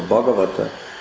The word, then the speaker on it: Bhagavata: Bhagavan Krishna, so Bhagavata is that with a long A.